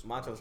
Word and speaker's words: Montrezl: Young Harzell. – Who? – Harris. Harzell. I say his name right? Harzell Harris on the Clippers. – Montrezl Harris.